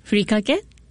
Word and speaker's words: furikake: The furikake, is that how you pronounce it? – Yes, furikake.